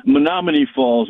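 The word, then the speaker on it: Menomonee Falls: You're pronouncing where he's buried at wrong. It's Menominee Falls in Wisconsin.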